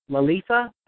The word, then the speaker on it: Lalitha: This is a song called, let me make sure I'm saying that, it's Lalitha, is that how you say it? – Yeah, that's right. You got it right.